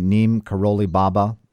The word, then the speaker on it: Neem Karoli Baba: And I'm making sure I say his name correctly, Neem Karoli Baba, the beloved.